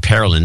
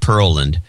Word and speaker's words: Pearland: Or he pronounced it Houston, not Houston. Well, of course it's not Houston. And Pearlin, not Pearland.